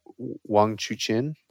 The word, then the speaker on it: Wang Chuqin: So the finals was, again, between Ma Long and Wang Chuqin.